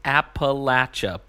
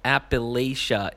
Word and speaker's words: Appalachia: He says, "Hey, Mike, it's pronounced Appalachia, buddy. I know you fellows are up in the great New England, but Appalachia is how folks say it when they don't know how to get any hillbilly on them.